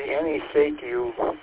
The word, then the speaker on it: ANYSECU: Annie Secu, A-N-Y-S-E-C-U, is how it's spelled, is on eBay.